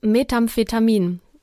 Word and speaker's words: Methamphetamin: Denn in diesem Teil von Oklahoma, in dem die Familie wohnt, wird nämlich in großen Mengen Methamphetamin war das richtig ausgesprochen? – Ja, in, ja.